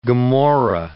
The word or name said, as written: Gomorrah